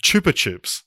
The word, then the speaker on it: Chupa Chups: And I found this out while watching the special feature about this, but Brazil has Rafael Nadal and Chupa Chups. – Chupa Chups? – Do you know it's pronounced Chupa Chup? Like the lollipop Chupa Chups.